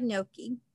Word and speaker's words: gnocchi: I've never had gnocchi.